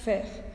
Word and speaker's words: faire: Eh, necesitamos otro verbo irregular en presente, que es el verbo hacer, que se escribe F-A-I-R-E, faire, pero se pronuncia FER.